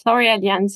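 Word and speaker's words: L'Oréalians: I think y'all refer to them as L'Orealians. Did I say that right? – Yeah, we do say that. L'Orealians, yes.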